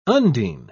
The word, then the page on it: undine: British English